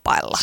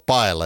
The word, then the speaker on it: paella: Karjalan piirakka, paella, tai lausutaanko se nyt paella? En mä osaa espanjaa. – Ei, nyt puhutaan suomalaisuudesta, niin sanotaan paella. – Suomalainen sanoo paella ja sillä selvä.